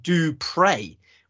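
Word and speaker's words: du Preez: The last word in his name is spelt P-R-E-E-Z, but it's pronounced Dupre, which is very appropriate because he is a man of God.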